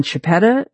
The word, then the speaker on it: Chiappetta: Look up my name, Ann Chiappetta. It's spelled Chiappetta, two P's and two T's.